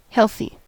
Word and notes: healthy: American English

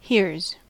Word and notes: hears: American English

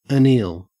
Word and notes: anneal: Australian English

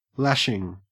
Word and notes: lashing: Australian English

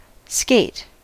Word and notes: skate: American English

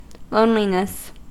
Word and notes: loneliness: American English

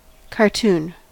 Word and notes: cartoon: American English

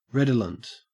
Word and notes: redolent: Australian English

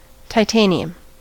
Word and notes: titanium: American English

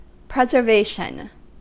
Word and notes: preservation: American English

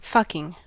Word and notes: fucking: American English

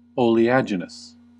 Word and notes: oleaginous: American English

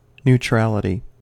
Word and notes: neutrality: American English

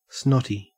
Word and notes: snotty: Australian English

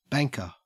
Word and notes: banker: Australian English

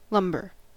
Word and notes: lumbar: American English

